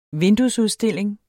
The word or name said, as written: vinduesudstilling